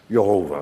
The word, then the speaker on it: Jehovah: Jehovah.